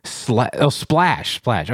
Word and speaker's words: SPLASH: Did I say that right? – Flash like water. – Splash. Okay.